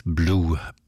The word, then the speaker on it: Bleu: Wie die Liebe für Vicky schreibt sie auch der Nachname des nächsten Musikers, also B-L-E-U. Ausgesprochen wird er aber als Blue, Benny Blue.